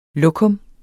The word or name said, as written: lokum